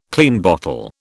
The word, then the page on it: Klein bottle: British English